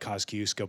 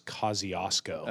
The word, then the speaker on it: Kosciuszko: It looks like Kosciuszko, but the pronunciation is Kosciuszko.